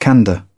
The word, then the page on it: candor: British English; əɹ